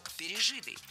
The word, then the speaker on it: пережитый: Если перед нами причастие, а здесь перед нами именно оно, то ударение ставим на третий слог – пережитый.